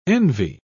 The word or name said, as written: envy